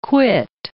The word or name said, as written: quit